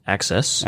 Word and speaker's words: AXS: So it's their GX version of Access, AXS, pronounced Access.